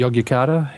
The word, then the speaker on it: Yogyakarta: Is that how you pronounce it? – Yes, correct? Yogyakarta.